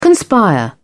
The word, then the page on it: conspire: British English